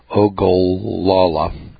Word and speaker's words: Ogallala: including the Oglala Aquifer, if I'm pronouncing that right, Ogallala, O-G-A-L-L-A-L-A. It's an Indian word, a little tricky for us white boys to pronounce.